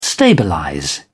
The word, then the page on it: stabilize: British English